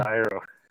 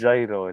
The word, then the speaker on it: gyro: The gyro. – Tzatziki? – It's not gyro. It's a gyro.